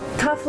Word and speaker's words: cough: Cough like handcuffs? – คุณผู้ชมครับภาษาอังกฤษเป็นภาษาที่บางครั้งเนี่ยนะครับการออกเสียงไม่แมทกับการสะกดอย่างแรงนะครับเช่นคำว่าอาการไอหรือมีการอาการไอนั่นเองครับ C O U G H ออกเสียงว่า cough cough for